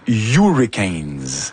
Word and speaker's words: Hurricanes: Je dis, c'est vrai que je dis souvent Hurricanes. – Et c'est Hurricanes? – C'est Hurricanes.